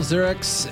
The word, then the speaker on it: Ziriax: Here we go, and we're on with Paul Ziriax.